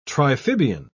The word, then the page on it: triphibian: British English